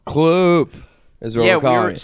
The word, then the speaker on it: CL30P: It's kind of Kloop, but the three is silent. – Yeah, so we don't really know how to pronounce it. – Thank that. – You for the pronunciation. – You add an extra O. Kloop. Silent three.